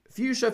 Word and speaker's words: fuchsia: Fuchsia, fuchsia, or fuchsia? Very good if you chose option number one. This is a horrible one because we say fuchsia, but it's really spelled like fuchsia.